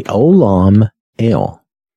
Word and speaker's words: Olam El: But here, the everlasting God, Olam, I'm sorry, let me say that correctly, Olam El.